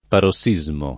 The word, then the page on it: parossismo: paro SS&@ mo